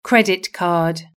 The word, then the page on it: credit card: British English